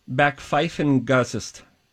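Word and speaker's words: Backpfeifengesicht: It is pronounced Backpfeifengagest.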